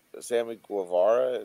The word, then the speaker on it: Sammy Guevara: Sammy Guevara, is that how you say it? – Yeah.